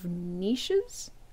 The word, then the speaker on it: niches: Okay, well, while you're examining the perfectly normal chair, the rest of you notice that the walls have a bunch of niches? I always forget how to pronounce that word. Did I? – No. – You got it.